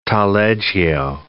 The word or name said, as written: taleggio